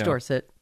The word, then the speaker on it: Dorset: Okay, so it's Dorset, England, which I'm sure is pronounced Dorsetshire, but... – It's, you know, Dorset.